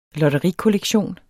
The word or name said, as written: lotterikollektion